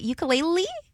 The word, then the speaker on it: ukulele: How do you say it? Ukulele? Or ukulele? – Ukulele. – Okay, ukulele.